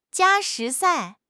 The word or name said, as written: jia1 shi2 sai4